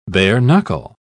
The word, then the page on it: bare-knuckle: British English